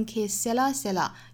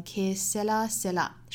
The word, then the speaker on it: que sera sera: 我们可以读成 que sera sera，也可以读成 que sera sera，因为这首歌也是后来被很多歌者翻唱过哈，所以我们也在不同的歌里听到过这句话的很多读法。这里面呢，我把它读成 que sera sera，是按照这首歌的原版的这个歌者唱的样子读出来的。